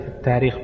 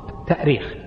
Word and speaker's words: التأريخ: أيهما أصح في الكتابة والنطق في كلمة التاريخ بالهمزة والتأريخ؟ طبعا من حيث الأصل الاشتقاقي التأريخ، التأريخ بالهمز، لأنها أرخ يؤرخ، الهمز أصلي فيه، أرخ يؤرخ تأريخا.